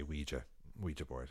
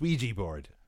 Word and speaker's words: Ouija: I don't think, we here in Ireland never say Ouija board. It's Ouija board. This is how it is.